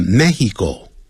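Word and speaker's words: México: 这如果要用西班牙话念这个的 Mexico, 知道怎么念吗？叫 Mexico, 这样念法。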